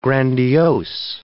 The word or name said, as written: grandiose